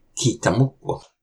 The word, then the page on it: Kihtamoq!: Algonquian